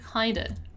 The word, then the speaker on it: kind of: Let's remember one more time that when I pronounce kind of in a fast speech, I'm gonna say kinda.